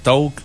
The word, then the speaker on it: Taake: Ça s'écrit T-A-A-K-E, mais ça se prononce Talk.